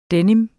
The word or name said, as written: denim